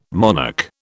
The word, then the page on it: monarch: British English